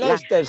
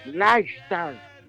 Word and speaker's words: Leicester: Bueno, el Leicester City, 2-2, hombre. – Leicester.